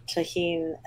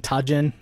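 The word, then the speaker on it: Tajín: And it's, like, the tahine and the seasoning and stuff. – Yeah, I would just like to point out to everybody I didn't white guy pronounce that by saying tajin, so it's okay.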